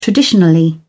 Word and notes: traditionally: British English

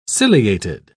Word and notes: ciliated: British English

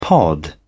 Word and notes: pod: British English

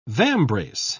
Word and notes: vambrace: British English

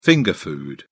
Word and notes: finger food: British English

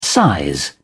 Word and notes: size: British English

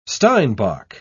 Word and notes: steinbock: British English

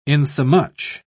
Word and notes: insomuch: British English

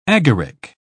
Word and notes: agaric: British English

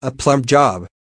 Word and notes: a plum job: American English

